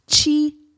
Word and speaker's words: chi: Chi activators. So chi is spelled C-H-I, and chi, or sometimes you'll see it as Q-I, and it's a Chinese word that means energy.